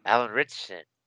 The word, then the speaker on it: Alan Ritchson: Alan Ritchson.